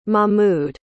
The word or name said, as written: mahmoud